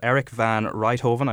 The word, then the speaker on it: Erik van Rijthoven: Erik van Rijthoven. I hope I've uh, pronounced that nearly okay. Yeah, that's good enough.